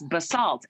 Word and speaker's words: Basalt: So then the drive to Basalt, and I thought it was called Basalt, and it's Basalt. And so then I was like, so when you guys, first of all, your city name rhymes with assault.